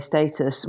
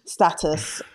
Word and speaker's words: status: Yeah, so first of all, the word status, you say status, I say status. We are both correct. So there's an American pronunciation, status, and the British pronunciation is status, but it's all the same thing.